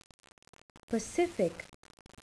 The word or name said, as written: pacific